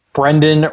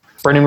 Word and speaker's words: Brendon: My name is spelled B R E N D O N, mm-hmm. not an A. There's an O.